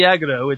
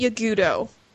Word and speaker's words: Yagudo: I thought it was Yagudo. we've always said Yagudo. – And yet another word you I've might. Never heard said offline until now. – Yeah. – Well, technically online still, person, but aloud is what I'm saying. – The person who founded the Link shell pronounces it Yagudo. So that's the pronunciation I am going to go with.